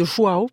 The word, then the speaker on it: João: Allez, 11 fois Fatima, ça sort aujourd'hui de João, parce que c'est comme ça que je l'ai entendu prononcer.